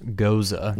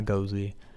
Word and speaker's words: Gose: G-O-S-E. Oh, if I read the label. – Yes, please. – Goza. Ghost, and then pronounced Goza. – Like so, Kaiser Sosa. Well, no. – But Goza, not Gozy.